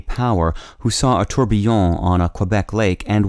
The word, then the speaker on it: tourbillon: Today's word is tourbillon, spelled T-O-U-R-B-I-L-L-I-O-N. Tourbillon also has a variant spelling and pronunciation that is closer to the French, spelled T-O-U-R-B-I-L-L-O-N and pronounced tourbillon.